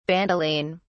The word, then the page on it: bandoline: British English